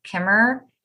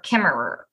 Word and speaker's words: Kimmerer: I'm not sure if it's Kimmer or Kimmerer, like Federer. – Oh, okay. It's spelled the second way, but it is. Yeah. It's got the two ERs at the end.